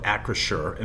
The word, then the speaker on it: Acrisure: So the CEO of Acrisure, and by the way, it's not that hard to pronounce once you take a little bit of time. It's three syllables.